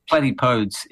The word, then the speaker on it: platypodes: But platypodes is actually if you wanted to go to the nth degree, that is the correct way to say it.